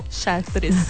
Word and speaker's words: Chartres: É a Suzana que pronunciou errado ali como Char, o quê? – Chartres.